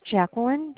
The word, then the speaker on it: Jacqueline: My name is Jacqueline, JACQUE.